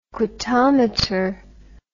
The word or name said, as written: quantometer